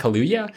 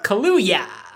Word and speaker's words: Kaluuya: How do you say his name? Daniel Kaluuya? – Kaluuya. – Yeah. It's like Kalua, but Kaluuya.